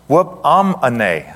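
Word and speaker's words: whoop-am-ane (ὑπομονή): There's a degree of it there, but this word right here, patience, in the Hebrew, it's pronounced whoop-am-ane, okay?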